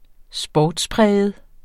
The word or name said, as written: sportspræget